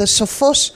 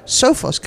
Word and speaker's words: Sophos: I'm at Mobile World Congress at the pavilion, the, the Sophos uh, pavilion. I'm going to say it Greek. I can't uh, do otherwise. I, I heard that the Americans say it Sophos company, and they're into security.